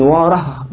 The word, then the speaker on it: واره: ابن واره دي ما تكتبش بالتاء المربوطة كما يفعل الناس، إنما تقول ابن واره بالهاء، زي إيه؟ ابن ماجه، هكذا هذه أسماء عجمية هكذا ينطقها أصحابها، واره ماجه لا تصرف ساكنة دائما معايا.